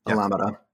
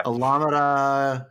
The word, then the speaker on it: Alameda: Alameda. – Yeah. – Alameda, NorCal.